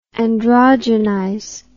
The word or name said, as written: androgenization